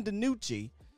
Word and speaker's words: DiNucci: But I called him Ben Houdini at first because I didn't know how to say his last name. So Ben DiNucci.